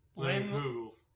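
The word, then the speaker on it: Leinenkugel: Linenkugel, with their, I guess it's seasonal, cranberry ginger shandy.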